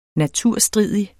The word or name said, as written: naturstridig